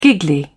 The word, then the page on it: giggly: British English